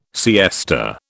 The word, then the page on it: siesta: British English